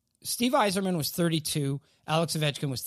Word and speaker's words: Yzerman: I believe that it's pronounced Steve Yzerman.